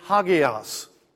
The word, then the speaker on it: hagios: The Greek word, if we were to spell it or say it in English, is hagias.